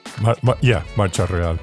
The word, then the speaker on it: Marcha Real: One of Marcha Real. Is that how you would pronounce it? – Marcha Real, yeah.